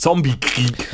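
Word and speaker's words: zombiekrig: Das wusste ich tatsächlich nicht, dass man das G nicht stark ausspricht. Zombie-Krieg heißt es und, und witzigerweise schreibt man Zombie doch Zombie-Krieg. Mit IE, also Zombie schreibt man normal und Krieg schreibt man ja auf Schwedisch anscheinend nur K-R-I-G.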